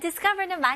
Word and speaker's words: discover: 대신 강세에 신경 좀 써주셨으면 좋겠어요. 그래서 discover가 아니고요. Discover, 강세가 어디에 들어가요? 네, ka에 들어가죠.